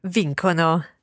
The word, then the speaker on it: vincono: And we're going back to the hard C sound there of vincono. Vinco and vincono. Because, of course, the C is followed by an O and not an I or an E. And that's our Italian pronunciation rules coming into play there.